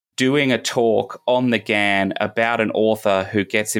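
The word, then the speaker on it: Ghan: I go up and down, and everyone in Australia pronounces it the GAN, but it's technically a shortened version of the Afghan Express was the original name, so GAN is how you're supposed to do it.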